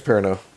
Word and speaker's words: Perrineau: Harold Perrineau, or Perrineau, I don't know how to pronounce it, and I think it's Perrineau. – Perrineau, okay, and then Idris Elba.